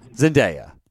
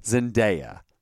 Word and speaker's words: Zendaya: Zendaya. Shoot. I messed her name up. – Okay, how do you say it? – Zendaya.